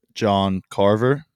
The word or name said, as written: John Carver